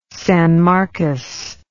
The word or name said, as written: San Marcos